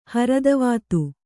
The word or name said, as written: ಹರದವಾತು